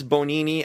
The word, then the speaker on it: Bonini: But no, it's Bonini. And the way Mara, I think, said it sounded like Bologna. That's how you pronounce it.